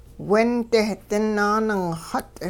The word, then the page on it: windeh dinaaningxut: Hupa